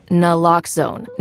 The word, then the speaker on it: Naloxone: For the record, it's Naloxone. Nuh, like, is Naloxone addictive? Nuh-uh. Lox, like, Naloxone keeps humans safe, like locks keep bikes safe.